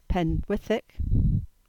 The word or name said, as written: Penwithick